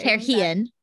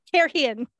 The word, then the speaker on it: Kyrian: And we find out he's in the foregate of Kyrian, is how we're saying. – Kyrian. – Kyrian. – Is how I will continue to say it. – I know. That's how it's spelled.